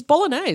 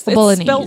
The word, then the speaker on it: Bolognese: Okay, so it's called a I hope I'm pronouncing it right. I think it's bolognese. – It's bolognese. It's bolognese. Spelled bolognese. – It's like, spelled bolognese. – Like the sauce, guys.